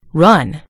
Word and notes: run: American English

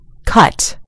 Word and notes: cut: British English

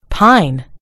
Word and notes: pine: British English

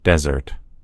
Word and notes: desert: American English

